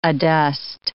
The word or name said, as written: adust